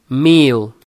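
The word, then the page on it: 1000: Brazilian Portuguese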